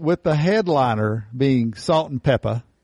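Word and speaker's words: Salt-N-Pepa: No, it's not Salt and Pepper. – Peppa. – Salt in Peppa, mm-hmm. – I think is how they would pronounce it.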